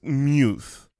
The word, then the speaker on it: Muth: It's pronounced Muth. Muth, but it's spelled M-U-T-H, which you would read Muth. – Yes. – Right? But his name dictates the M-U-T-H because...